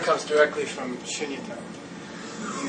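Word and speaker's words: Shunyata (शून्यता): Shunyata. – Shunyata.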